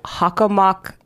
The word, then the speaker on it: Hockomock: O-M-O-C-K. Hold on, someone spell that back to me. Did I do that right? – There's no A's. H-O-C-K-O-M-O-C-K. – Yes. Hockomock. Hockomock. But that's how they say it. They say Hockomock.